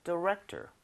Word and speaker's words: director: math, but now I'm a movie director. – 미디렉. 어? 아이. – T-O-R. – 디렉토리구나, 디렉톨. – 근데 발음은 director 맞는데, 너무 tour는 아니고요.